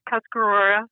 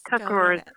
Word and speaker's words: Tuscarora: It's Tuscarora, and that is the English word for it. It's really Scarorette, but, you know, it's Tuscarora.